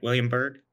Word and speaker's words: William Byrd: Am I saying that right? William Byrd? – Correct.